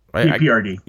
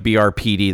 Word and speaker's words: BPRD: I collected BRP or what. – BPRD. – BPRD. Did I say that right? Okay. I was like BRPD. That isn't right.